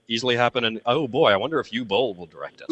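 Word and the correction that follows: Uwe: Uva. Mispronounced his name.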